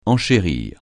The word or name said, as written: enchérir